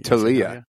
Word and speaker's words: Thalia: And the capo that Patrick and I both referenced in our Say Thalia. You're gonna Oh sorry. – Talia. – Talia. There you go.